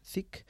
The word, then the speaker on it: Thicke: Thicke.